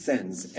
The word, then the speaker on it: Sens: We call it Shans, but in French it's Sens, S-E-N-S.